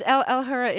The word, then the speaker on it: Al-Hurra: Are you still doing your show on Al-Hurra? Am I saying that right? Yes. And this is Al Hura is an Arabic cable station that's funded by U.S.